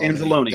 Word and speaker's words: Anzalone: I think that's how you pronounce it. Anzalone. Alex Anzalone. – Anzalone.